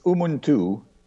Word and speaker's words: Umunthu: It's Umuntu. It's a word from the local language, Chichewa.